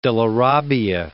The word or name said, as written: Della Robbia